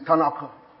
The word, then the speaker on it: Tanakh: Someone says, it's Tanakh. Tanakh. Tanakh. I'm Yoruba. It's spelled T-A-N-A-K-H. Something of that nature. T-A-N-A-K-H.